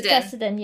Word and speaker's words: Fessenden: Fessenden, yeah.